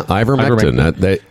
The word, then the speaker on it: ivermectin: Yeah, ivermectin.